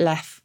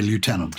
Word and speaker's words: lieutenant: And I have to address the why is it lef when it's spelt lieu. And the idea, we think, is that someone misread the U for an F because the characters looked very similar centuries ago. And that's why we pronounce it that way, even though we didn't change the spelling.